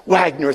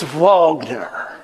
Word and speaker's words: Wagner: I just want to remind you that it's not actually pronounced Wagner, it's Wagner.